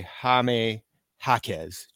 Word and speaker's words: Jaime Jaquez: Yes, going to Miami, triple soft J, or the real triple J, Jaime Jaquez Jr. for the Miami Heat over the past four games.